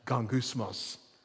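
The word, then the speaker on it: gongousmos (γογγυσμός): By the way, this word grumble is translated from a Greek word that's pronounced gongousmos. It just sounds like grumbling, right?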